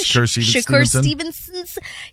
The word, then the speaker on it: Shakur Stevenson: Shakur? – Yes, Shakur Stevenson.